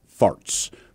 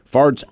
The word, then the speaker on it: Faartz: His name, and this is not a lie, by the way, his first name is spelled W-H-E-T, Dr. Wet Farts. Farts, F-A-A-R-T-Z. He's a real person. He's been interviewed many times. – W-H-E-T, first name? – W-H-E-T, Dr. Yeah.